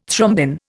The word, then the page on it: thrombin: Indian